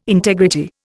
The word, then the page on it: integrity: Indian